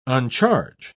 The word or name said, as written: uncharge